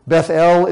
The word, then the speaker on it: Bethel: So we Americans would incline to call this city Bethel. But in Hebrew, it would be pronounced Beth-El. And the reason for that is because El, E-L, is the Hebrew name for God. And so, of course, the emphasis would be on God's name, El. So they would pronounce it Beth-El.